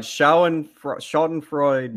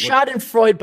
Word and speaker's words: Schadenfreude: Schadenfreude. – What's Schadenfreude, but I call it.